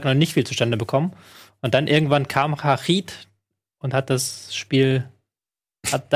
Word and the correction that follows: Harit: Heißt er nicht lang? Harit. Harit. Harit.